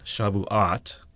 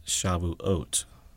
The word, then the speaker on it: Shavuot: And this has an O sound, not an ah sound. It's not Shavuot, it's Shavuot. Keep that in mind whenever you see a transliteration from Hebrew into English. The OT ending is pronounced Ot.